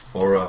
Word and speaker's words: hora: Hora? Hora? – Yeah. Hora, hora.